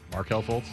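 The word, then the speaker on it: Markelle Fultz: Mikal Fultz. – Markel Fultz. – Yeah, I know. I love butchering his name.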